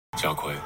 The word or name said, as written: Jia Kui